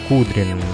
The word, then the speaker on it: Кудрин: Так вот, совсем недавно российский либеральный политик, зовут его Алексей Кудрин, если я правильно, конечно, поставил ударение, потому как не особо знаком с ним. Да, правильно, посмотрел вот в Гугле, на Википедии пишут Алексей Леонидович Кудрин, российский политик и экономист.